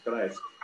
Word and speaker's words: Kreisky: Ele recebeu o prêmio de direitos humanos da Fundação Bruno Kreisky. É assim também, Frei, que pronuncia? – É assim mesmo, Kreisky.